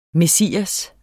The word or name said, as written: messias